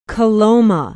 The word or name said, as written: Coloma